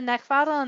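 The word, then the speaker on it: Neckvatal: You can find me over in Connect and LinkedIn by just typing in my name, which is Brenda Neckvatl, and that's spelled N-E-C-K, V as in Victor, A-T-A-L.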